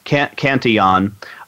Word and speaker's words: Cantillon: A guy by the name of Richard or Ricard, it probably is because it's French pronounced Cantillon.